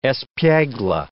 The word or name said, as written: espiegle